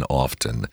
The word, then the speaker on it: often: I know that I was taught to pronounce that T in often.